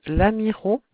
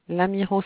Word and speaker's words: Lamirault: 2 rue de Lamiro. Lamiro s'écrit L-A-M-I-R-A-U-L-T.